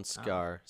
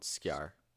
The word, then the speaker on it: Kjær: Simon Skjær. Simon Skjær. – It's like K-J-A-E-R, right? – S.